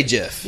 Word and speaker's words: GIF: You see that gif of her getting like backstabbed or whatever? – Hold on, wait. Pronounced how? – Gif. – Is it really? – I say Jeff.